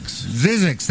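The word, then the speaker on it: Zzyzx: Zizix, thank you. That's how you pronounce it? Mark C.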